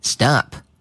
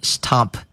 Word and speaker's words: stop: 停止、中断可以说成 stop，拼写拼成 s t o p stop。那么我们注意读音，千万不要光看音标读，读成 stop，这样不对，要把 st。这个音发成浊化音，发成 stop，stop，好，stop，停止，中断。